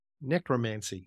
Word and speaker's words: necromancy: How do you pronounce it? – Necromancy. – Yeah, necromancy.